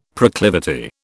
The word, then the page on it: proclivity: British English